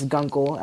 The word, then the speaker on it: Guncle: Yep, you can follow me in Gunkle Name J. – Gunkle Name J. Spell that for them. – That's Gunkle, as in gay uncle. G-U-N-C-L-E, named, and then J, as in Jules.